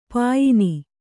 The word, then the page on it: ಪಯಣ: kannada